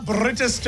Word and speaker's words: Bridestow: Britisdow Lavender Farm. I think I'm saying it right. I actually Googled the pronunciation because it's spelt Bridestow.